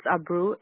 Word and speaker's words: Abreu: It's Abreu, A-B-R-E-U.